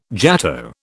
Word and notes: jato: British English

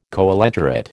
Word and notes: coelenterate: American English

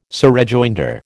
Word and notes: surrejoinder: American English

